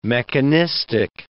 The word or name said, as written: mechanistic